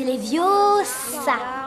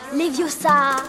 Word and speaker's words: Leviosa: Et puis d'ailleurs, tu ne sais même pas le prononcer. C'est Leviosa. Et pas Leviosa.